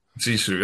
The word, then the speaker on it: Sisu: Sisu. – Sisu, that's how you say it.